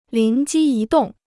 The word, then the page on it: 灵机一动: líng jī yī dòng